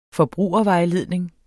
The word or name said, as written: FORBRUGERVEJLEDNING